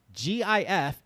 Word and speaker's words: GIF: He said, I'm convinced GIF is pronounced with a hard G, as in gift, because if you say it with a soft G, as in gentle, you're talking about peanut butter.